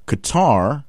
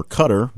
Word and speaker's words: Qatar: Qatar, or Qatar, based on how you want to pronounce it,